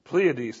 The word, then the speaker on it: Pleiades: Pleiades.